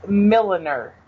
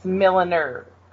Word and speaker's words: Milliner: Milliner. – Milliner. – Oh, I thought it was Milner. – It's Milliner. Originally, milliners were hat makers.